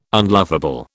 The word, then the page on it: unlovable: British English